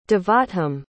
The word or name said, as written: devottham